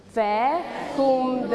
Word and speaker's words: vacuum: Vacuum the floor. That's right. Vacuum. ตัว V นะคะจะมีเสียงเฟอร์เหมือนเสียงฟอฟันเล็กน้อยเขาเรียกออกเสียงว่า Vacuum the floor.